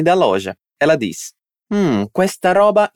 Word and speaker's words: roba: Dilla com'è, fai attenzione alla pronuncia della R. Roba. Roba. Perfetto.